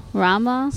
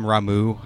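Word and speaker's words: Ramuh: Rama. Some people call him Ramu. I don't know if I agree with that, but I just say Rama.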